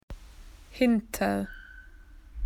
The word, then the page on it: behind: Australian English